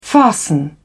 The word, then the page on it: fasten: British English